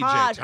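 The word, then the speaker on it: Tej: It's Taj. It's T-E-J, Taj.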